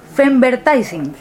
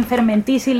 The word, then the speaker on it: femvertising: cómo se pronunciaría, femvertising, ¿es correcto? – Es correcto. – Femvertising y fermentising le he estado diciendo durante meses, hasta que hoy María me ha dicho, pone.